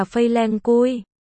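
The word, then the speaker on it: Phây Leng Cui (翡冷翠): Nguyên văn từ đó là Feilenkui, phiên âm là Phây Leng Cui.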